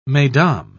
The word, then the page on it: mesdames: British English